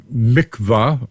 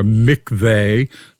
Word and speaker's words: mikveh: The technical term is a mikvah or a mikveh. Some people would pronounce it, usually spelled something like M-I-K-V-E-H.